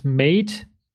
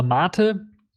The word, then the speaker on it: MATE: Also was wird mit Mate oder Mate, je nachdem, wie man es aussprechen möchte, der ja auch so langsam auf GTK 3 umgeswitcht werden soll.